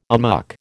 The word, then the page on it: amok: American English